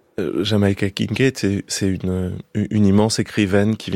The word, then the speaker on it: Jamaica Kincaid: La voix de Jamaica Kincaid, je prononce bien son nom.